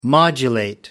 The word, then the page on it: modulate: American English